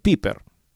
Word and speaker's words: Pieper: Bueno, Joseph Piper, Pieper se escribe, Joseph Pieper.